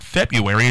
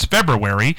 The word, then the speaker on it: February: It's not February, it's February, but 90% of the people say February, and I would say the same thing with caramel.